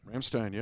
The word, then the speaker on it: Ramstein: But we have Charles at Ramstein Air Force Base in Germany. Did I pronounce that right? – Ramstein, yeah.